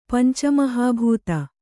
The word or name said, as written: ಪಂಚಮಹಾಭೂತ